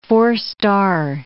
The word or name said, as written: four-star